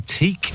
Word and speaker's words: Tique: Hi to the ladies who teak. I think that's how you say it. T-I-Q-U-E.